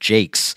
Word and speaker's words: Jacques: Spelled, you know, Jacques or Jacques, but I believe he pronounces it Jakes.